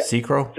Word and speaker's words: Sekiro: Sekiro.